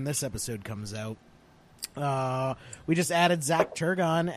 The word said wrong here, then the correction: Turgeon: as our fourth and final headhunter for the event. So. – Turgeon. – Yeah, whatever. – Turgeon. – Rhymes with sturgeon.